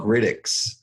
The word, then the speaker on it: Grytics: is there's something called Grytx. I don't know if you've heard of Grytx before. It's spelled Grytics.